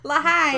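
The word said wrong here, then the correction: L'chaim: Sort of like Lachaim.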